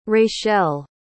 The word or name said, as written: rachele